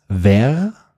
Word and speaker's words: verre: Ja, das Spannende ist, dieses letzte Wort, dieses Ver, das schreibt man V-E-R-R-E, Und ja, du als quasi Native Speakerin fast, kannst dieses Wort vielleicht mal übersetzen?